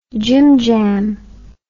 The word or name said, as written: jimjam